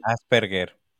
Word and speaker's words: Asperger: Asperger.